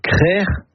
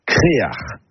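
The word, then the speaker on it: Créac'h: Le mot breton, c'est créar. Et ce mot, on le retrouve dans des hameaux ou des quartiers, même pour un phare. Et la bonne production est créer ou créar. Créar, c'est une prononciation plus du Nord Finistère, mais on la trouve parfois ailleurs.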